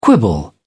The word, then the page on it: quibble: British English